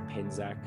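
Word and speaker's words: Penczak: Is one of Christopher Petchak's books. Oh, sorry, Penzack. I always say it like Czech way, not the American way.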